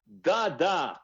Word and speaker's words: Dada: Lui si chiama Dada con l'accento sulla A,